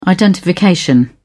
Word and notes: identification: British English

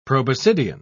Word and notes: proboscidian: British English

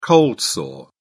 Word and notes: cold sore: British English

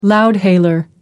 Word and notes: loudhailer: American English

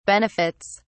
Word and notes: benefits: British English

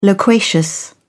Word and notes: loquacious: British English

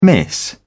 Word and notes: miss: British English

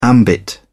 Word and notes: ambit: British English

